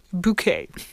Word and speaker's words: Bucket: As she made clear throughout the show's entire run, B-U-C-K-E-T is pronounced bouquet.